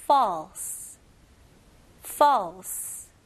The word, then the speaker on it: false: 여기서는 a, false. 턱이 내리는 느낌입니다. false, false. Very good.